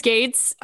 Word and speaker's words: Gaetz: Isn't it Gaetz? – I don't know. – I mean, do we care? – We don't care. – I think I'm pretty sure it's Gaetz.